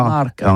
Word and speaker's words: marcat: Eh bien, d'ici à toutis, mais le mercat, de l'Uni ou le mercat des rebelles, mais en gascogne toulousano, c'est prononcé sur les marcat, avec un A, marcat.